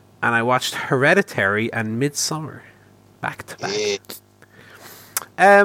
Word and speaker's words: Midsommar: It's Midsommar, is what it's called. – It's just, yeah, they say it's weird because I see loads of people pronounce it like with a hard A.